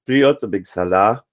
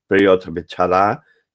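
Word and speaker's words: Piotr Beczała: Lo haremos en las voces de Sonia Honcheva, Priot Bexalá, o como parece que se pronuncia, Piotr Bechalá y Plácido Domingo, acompañados por la Metropolitan Opera Orchestra dirigida por Bertrand de Billy, según un registro en vivo de 2018.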